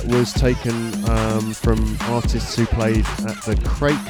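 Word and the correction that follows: Krake: Festival Well, I've been corrected, it's pronounced Kraker or something along those lines.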